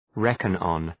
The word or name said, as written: reckon on